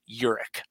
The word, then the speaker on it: Huryk: If you look at it, it's spelled Huryk, but it said Huryk.